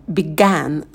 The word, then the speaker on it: began: Первый – begin. И, краткий и. Потом began, звук лягушка, когда мы улыбаемся, чуть отстраняем вниз и быстро ставим на место. Begun, краткий а, рот минимально открывается.